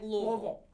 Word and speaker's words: Louisville: No, people in Louisville say, like, Louisville. I've been corrected. – I believe it's Louisville. People have corrected me multiple times, though.